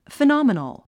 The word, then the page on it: phenomenal: finάmənl; フィノォミノォ